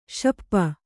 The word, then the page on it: ಶಪ್ಪ: śappa